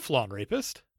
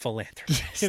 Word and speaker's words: philanthropist: He's a flawn rapist. – It's pronounced in philanthropist.